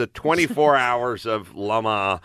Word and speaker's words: Le Mans: You say Le Mans, but it's kind of Le Mans is the way they say it.